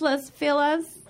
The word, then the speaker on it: Los Feliz: It's pronounced Los Feliz. It's pronounced Los Feliz. I'm Latina, guys. It's pronounced Los Feliz, all right? White people, just because you can't say it doesn't mean the pronunciation magically changes.